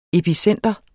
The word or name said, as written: epicentrum